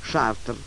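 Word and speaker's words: Chartres: «Шартер?» «Да, это слово по-французски пишется «Шартрес», а читается «Шартер».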